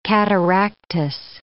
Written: cataractous

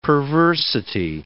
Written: perversity

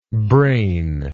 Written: brainlike

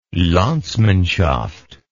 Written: landsmanshaft

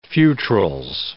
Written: fewtrils

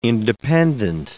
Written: independent

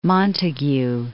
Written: Montagu

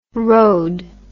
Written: rhod

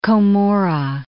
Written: Komura

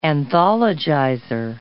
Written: anthologizer

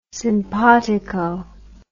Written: sympatico

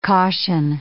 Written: caution

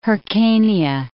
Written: Hyrcania

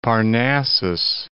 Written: Parnassus

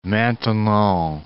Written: Maintenon

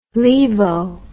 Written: laevo

